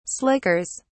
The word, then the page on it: slickers: British English